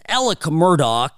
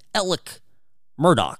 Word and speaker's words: Alex Murdaugh: Now, Alec Murdaugh, it's spelled Alex Murdaugh. But for whatever godforsaken reason down there in South Carolina, these people pronounce things differently. So I'm going with how he pronounces his name, Alec Murdaugh.